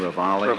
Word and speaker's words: Ravalli: Is that the correct pronunciation, Ravalli? – Ravalli, yes, Your Honor.